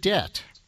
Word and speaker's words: debt: So the word debt is also there at number 40, a word much in use these days. And you write that the presence of the silent B tells us something about class as well.